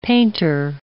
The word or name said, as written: painter